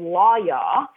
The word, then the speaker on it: Lawyaw: So the reason why I call this so just a bad revelation, amazing achievement is because I demoed this software called Lawyaw, and it's spelled L-A-W-Y-A-W. And it's kind of like, I was actually talking about it with my boyfriend and he's like, oh, like Lawyaw, like get a lawyer.